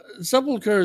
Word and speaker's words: Sepulchre: No. Sepulchre is how you say it, but how did you say that? – Spelosha.